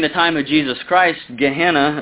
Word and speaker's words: Gehenna: Gehenna, and it's spelled G-E-H-E-N-N-A, Gehenna, and Gehenna literally does point to hellfire.